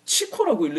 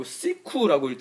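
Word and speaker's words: Chico: 그 다음에 루시드 폴이 이번에 책을 하나 번역했는데 이게 치코라고 읽는 게 아니고 시쿠라고 읽더라고 시쿠 부아르키 음.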